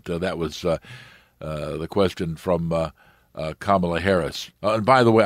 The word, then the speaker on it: Kamala: this is Senator Kamala Harris." That's how you pronounce the name, like C-O-M-M-A hyphen L-A, Kamala.